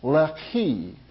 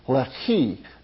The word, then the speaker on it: Lehi: Now the Philistines went up, encamped in Judah, and deployed themselves against Lehi, is how it's pronounced. Lehi is in the English text, but it's Lehi.